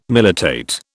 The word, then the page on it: militate: British English